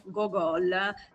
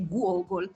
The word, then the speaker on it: Gogol: Tu dicevi appunto che erroneamente noi diciamo Gogol Ostello, Gogol. Mi hanno detto che la pronuncia giusta è Gogol.